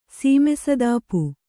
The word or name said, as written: ಸೀಮೆಸದಾಪು